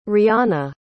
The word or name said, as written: rhianna